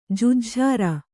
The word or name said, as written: ಜೆಜ್ಝಾರ